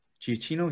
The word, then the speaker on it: Giacchino: Is that how you pronounce the name? Giacchino.